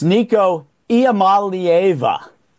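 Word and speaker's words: Iamaleava: Well, for your audience, it's Nico Iamalieva is the pronunciation.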